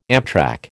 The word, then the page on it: amtrac: American English